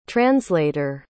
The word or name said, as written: translater